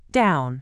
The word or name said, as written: down